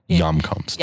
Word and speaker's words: Hjemkomst: It's spelled H. I should say, since this is a podcast, I should spell H-J-E-M-K-O-M-S-T. – It's awesome. I think I love the way it's spelled and that it sounds nothing like it when you say it.